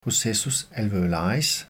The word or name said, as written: processus alveolaris